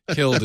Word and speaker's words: kiln: K-I-L-N. He's pronouncing it properly. – Killed. – Killed is how you say it, though.